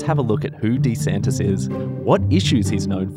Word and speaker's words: DeSantis: That's because in his campaign videos, that's how he pronounces his name, DeSantis.